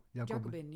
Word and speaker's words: Jacobin: Of zeg ik het goed zo? – Jacobin, ja. Dat ja. is eh, correct.